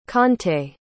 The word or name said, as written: conte